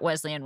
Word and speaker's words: Wesleyan: Wesleyan.